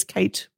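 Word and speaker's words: Cate: Well, and me, I am on LinkedIn, obviously under my name, and it is Kate with a C. Someone asked me the other day how to pronounce my name. It's like Kate with a K, but it's a C.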